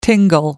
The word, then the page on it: tingle: American English